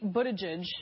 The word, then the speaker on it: Buttigieg: So Pete Buttigieg, mm-hmm. – which that's how you say it. That's how he said it, I should say. I checked.